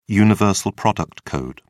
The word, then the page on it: universal product code: British English